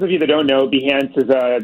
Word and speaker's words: Behance: Was it Behance, right? – Yeah.